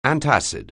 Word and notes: antacid: anglais

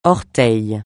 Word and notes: orteil: francais

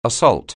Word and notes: assault: anglais

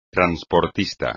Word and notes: transportista: espagnol

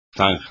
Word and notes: zanja: espagnol

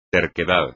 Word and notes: terquedad: espagnol

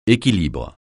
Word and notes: équilibre: français

